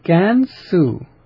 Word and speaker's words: Gansu: But the pronunciation of Gansu is this province. Is the way that this name is pronounced. Gansu.